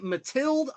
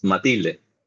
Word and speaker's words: Matilde: Matilde? I've heard it pronounced so many different ways. – It's Matilde.